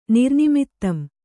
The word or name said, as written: ನಿರ್ನಿಮಿತ್ತಂ